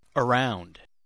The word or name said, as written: around